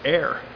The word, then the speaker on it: heir: When Elisha asked for a double portion of the spirit of Elijah, he was in essence asking to become Elijah's heir. Maybe I said that wrong. Elijah's heir. Elijah's son. H-E-I-R.